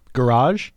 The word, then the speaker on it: garage: And the Brits usually say garage, which is a classic British pronunciation and a classic English pronunciation for a word, where we a lot of times stress the first syllable.